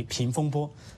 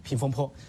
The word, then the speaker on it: 风波: 然后习近平提到了，他说是应变局平风波，只是把坡读成了那个波读成了坡，平风波，平风坡。